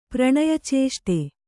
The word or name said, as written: ಪ್ರಣಯಚೇಷ್ಟೆ